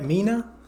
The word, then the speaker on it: Mina: Mina? – Yeah.